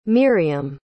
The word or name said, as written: Myriam